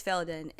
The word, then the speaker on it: Faeldan: Actually, it's like different. So in Australia, it's different. In the Philippines, it's different. Here, it's Faeldan'S.